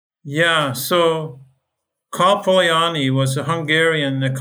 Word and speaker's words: Polanyi: Karl Polanyi, yeah.